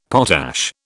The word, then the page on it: potash: British English